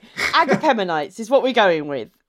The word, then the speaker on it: Agapemonites: Agapemonites. – I'm pretty sure that's how you pronounce it, Agapemonites.